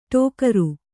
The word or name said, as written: ಠೋಕರ